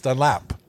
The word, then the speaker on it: Dunlap: Somebody got on me hard saying that I had mispronounced Nick Dunlop's name. – Wow. – I was, just did it as well. – No, Dunlop. It's Dunlap. That's like how Montanans say Dunlop.